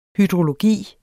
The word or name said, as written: hydrologi